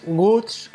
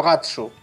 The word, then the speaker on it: Guts: Sí, es que el nombre real es Guts, que digamos, que es en inglés es Agallas. Uh-huh. Lo que pasa es que por la pronunciación en japonés, pues a veces te suelen decir cómo se pronuncia. Entonces puede decirte Ogats o a veces incluso Gatsu. – Ah, Gatsu. – ¿Vale? Ah. Pero la idea original era Guts de Agallas.